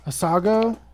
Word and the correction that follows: Asiago: Asiago. Asiago. What the fuck is wrong with you? – There's no I in it.